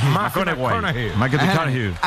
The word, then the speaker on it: McConaughey: Matthew McConaughey. – Sí. – Que hay que ir corrigiendo la pronunciación. – McConaughey.